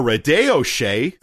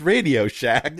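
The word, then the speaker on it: RadioShack: A Radio Shay. Okay. – A Radio Shay. – You know how to pronounce words that clearly says Radio Shack.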